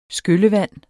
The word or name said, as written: skyllevand